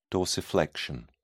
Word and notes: dorsiflexion: British English

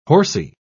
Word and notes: horsy: British English

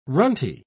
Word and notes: runty: British English

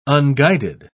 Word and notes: unguided: British English

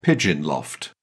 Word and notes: pigeon loft: British English

